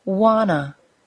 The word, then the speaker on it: wanna: Wanna. W-A-N-N-A. Wanna is the combination of the words want and to.